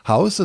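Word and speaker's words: Haus: Funny enough, house is spelled differently in German and in English, but it's pronounced the same, house.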